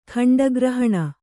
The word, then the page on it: ಖಂಡಗ್ರಹಣ: khaṇḍa grahaṇa